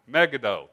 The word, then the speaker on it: Megiddo: Megiddo.